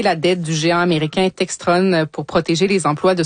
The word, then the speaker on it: Textron: Oui, ben, en fait, Textron, il le prononce comme ça en anglais, évidemment.